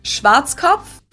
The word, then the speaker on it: Schwarzkopf: In German, we pronounce it Schwarzkopf. – Kopf? Nah. Schwarzkopf. Schwarzkopf, mate. – Blackhead. Schwarzkopf. English native speakers usually pronounce it Schwarzkopf or Schwarzkopf or something along those lines.